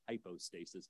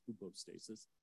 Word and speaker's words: hypostasis: It's often said hypostasis, but it's actually pronounced hypostasis.